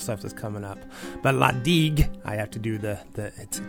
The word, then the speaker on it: La Digue: is La Digue, and that is pronounced correctly.